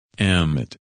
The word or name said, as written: Emmett